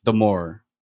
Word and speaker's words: D'Amore: First of all, it's pronounced D'Amore. It rhymes with adore.